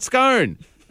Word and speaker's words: scone: What are those things you eat ice cream out of? – Cone. – Cone, right? Slap an S on the front, scone.